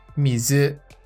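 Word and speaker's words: mizu (みず): O próximo kanji é o kanji de água, ao qual pronunciamos mizu, mizu.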